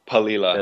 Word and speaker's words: Palila: It's a pelele bird, correct? I can't pronounce the word, the name right. – Yeah, the Palila.